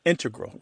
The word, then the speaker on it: integral: Just to clarify here, both correct, but the mathematicians and math-using scientists are more likely to integral with the stress on the first syllable.